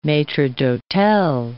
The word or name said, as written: maitres d'hotel